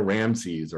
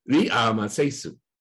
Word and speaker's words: Ramses: But by the time you can really work out what the original pronunciation was, and so the pronunciation of Ramses was originally Ri-a-ma-se-su. So very different from Ramses.